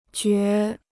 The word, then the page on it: 觉: jiào